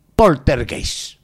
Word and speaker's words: Poltergeist: Hoy va a hablar de Poltergeist.